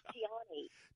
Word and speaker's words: Tiani: Well, actually, you made it sound pretty good. It's Tiani.